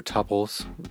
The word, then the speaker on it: tuple: We haven't talked about tuples yet, or tuples. They're sometimes pronounced. I pronounce it tuple.